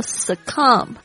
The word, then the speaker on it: succumb: Dare it again and again, and it will succumb. 它就会 succumb. S-U-C-C-U-M-B, 特别注意一下这个 M-B 的这样子的组合的单词，通常 B 不会发音，不要念成 succumb.